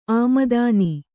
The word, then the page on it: ಆಮದಾನಿ: āmadāni